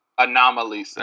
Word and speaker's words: Anomalisa: Anomalisa. – Anomalisa. Like, okay. – Like the girl he falls in love with, her name is Lisa, so it's like Anomaly and Lisa, because she's unique or whatever.